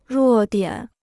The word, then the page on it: 弱点: ruò diǎn